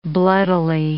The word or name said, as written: bloodily